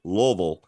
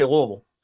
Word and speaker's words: Louisville: The way you know that that Matt is not actually from the town in Kentucky that he mentioned is he did not pronounce it Louisville, which is how natives will pronounce it. And boy, I tell you, I've said Louisville on a microphone and I've been physically accosted, Matt.